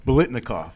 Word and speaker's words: Biletnikoff: How do you say that? Biletnikoff? – Biletnikoff. – Biletnikoff, man. The wide receiver, best wide receiver in the nation award.